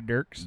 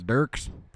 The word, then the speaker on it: Dierks: It's country music, so it's usually Dierks. – Dierks. Dierks Bentley. – Bentley. – Dierks Bentley.